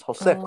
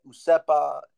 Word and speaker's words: Josefa: The boat's name was Josefa. It was Josefa. Oh. – And it looks like probably some some American read that, didn't know how to pronounce it, and just said Usepa, you know, and that's it.